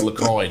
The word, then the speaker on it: LaCroix: And this is LaCroix. Yes. I was like, have I been saying that wrong all along? – No, you're good.